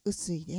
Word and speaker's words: 雨水: 雨に水と書いて雨水です。